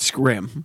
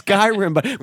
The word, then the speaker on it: Skyrim: I got that for the little guy once, and he was pronouncing it Skrim. – Right. – I'm like, I think it's Skyrim, but there's no space.